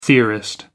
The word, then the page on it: theorist: American English